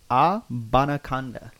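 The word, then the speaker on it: Abanikanda: It's pronounced Abanaconda. So, pretty close.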